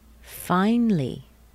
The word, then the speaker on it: finally: And the next one? Finally. Finally. The A is gone. Finally. Two syllables. And a nice long word. Theoretically. Remember that C-A-L-L-Y will be pronounced cli.